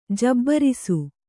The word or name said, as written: ಜಬರಿಸು